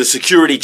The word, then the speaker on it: security: 우리가 경비원을 얘기하는데 그래서 S-E-C-U-R-I-T-Y는 발음이요. Q의 액센트가 있기 때문에 리 발음이 강하게 안 나요. 그러니까 security 발음을 하기 오히려 힘들어요. 그래서 Q를 강조하기 때문에 리가 러가 되는 거예요.